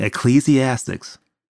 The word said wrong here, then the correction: Ecclesiastes: You know? Ecclesiastes? Please. Did I say it right or wrong? I don't know. Uh, I'm not even sure if ah. I'm saying it right. Ecclesiastes. – Yeah.